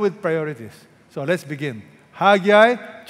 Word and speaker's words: Haggai: Haggai. People don't know how to pronounce this, okay? So, I'm going to tell you now. Say that with me. Haggai.